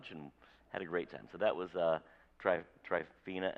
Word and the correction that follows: Trefina: and Mike, or Trefina, I think is how she pronounces it, Trefina and Mike.